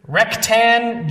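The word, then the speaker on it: Rhek'tan: Rektan Jorvan. That first name is spelled R-H-E-K apostrophe T-A-N.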